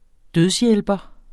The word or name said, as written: dødshjælper